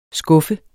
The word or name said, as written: skuffe